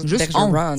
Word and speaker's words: Bergeron: Normalement, il écrit ça. Bergeron. – C'est ça, tu ne prononces pas le N, juste on. Ils ne sont pas capables.